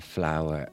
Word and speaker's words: flour: Clar, flour, like a rose is W. I flour to make bread is F-L-O-U-R. – Sí, sí, s'escriuen completament diferents. – Yes. – Però es pronuncien igual.